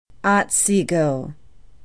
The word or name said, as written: otsego